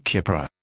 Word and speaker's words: Keppra: This is the consumer medicine information for Keppra Oral Solution and Tablets, pronounced Keppra. Active ingredient, levetiracetam, pronounced levetiracetam.